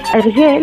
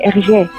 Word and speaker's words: Hergé: de manera que pronunciadas en francés sonase RG. – Sin embargo, la licencia creativa de Hergé terminó con este detalle,